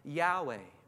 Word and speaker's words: Yahweh: And in the original Hebrew, that name is pronounced something like Yahweh.